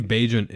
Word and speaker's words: Bagent: In the meantime, we get to watch Bagent sling it around, which I believe Bagent is the correct way to pronounce his last name.